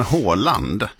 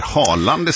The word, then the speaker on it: Haaland: Så är det, och vad bra, jag är tacksam över att du säger Håkon, för att det är lite som Håland, fotbollsspelaren, alltså norska, att folk till höger och vänster säger Haaland istället, därför att så uttalar ju engelsmännen det, till exempel när man hör referat och sådär, men 2 A är ju Å.